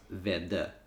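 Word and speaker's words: Väddö: It's spelled V-A-D-D-O. And it's got the A with the two little dots over it and the O with two little dots over it. And it's pronounced Vedda.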